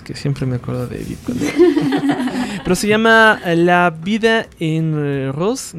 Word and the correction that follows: La Vie en Rose: no sé cómo se pronuncia. – La Vie en Rose.